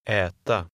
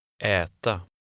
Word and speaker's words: äta: In number 16, the final A is unstressed and makes a slightly different sound. Listen and repeat. – Äta. Äta.